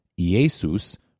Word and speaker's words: Iesous: They had to write it a little differently, and what they wrote it as was Iesus, which is spelled I-E-S-O-U-S if you transliterate the Greek letters to English letters. And the reason is because Quine Greek didn't have a letter that would do the sh sound, so they just use an S.